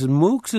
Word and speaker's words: MOOCs: And the UW has announced expanding its offering of, I don't know, is MOOCs is a proper pronunciation, massive open online courses? – That's the right pronunciation.